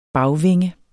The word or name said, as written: bagvinge